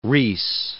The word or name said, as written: Riis